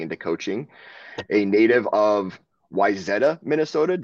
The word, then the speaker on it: Wayzata: Wayzata is, a lot of people say Wayzata, but that's good. Wayzata, that's the way to go.